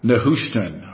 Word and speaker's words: Nehushtan: And it was called Nehushtan, I believe is the correct pronunciation of that.